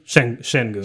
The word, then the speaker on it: Şengün: Şengün. – Şengün.